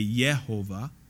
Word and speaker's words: Yehovah: Yahweh is probably closer to the original since there is no J sound. There's no J in the Hebrew language. It is a Y. So if it is Jehovah, it's more proper to say Yehovah in that particular pronunciation.